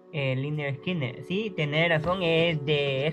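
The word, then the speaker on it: Lynyrd Skynyrd: Lineard Skinner su disco debut, Pronounced Leonard Skinner.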